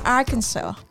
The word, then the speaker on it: Arkansas: So I've googled it. So it's pronounced as Arkansas.